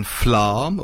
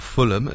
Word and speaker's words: Fulham: He was born in Flam, or Fulham, as some people foolishly pronounce it, down in Lahandon.